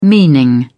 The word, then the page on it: meaning: British English